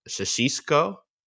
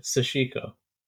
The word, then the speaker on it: Sashiko: Is it uh Shashiko? Shashiko? Shashiko. – Oh, uh, so almost there. – Sashiko. Shashiko. Okay. Yeah, Sashiko. Okay.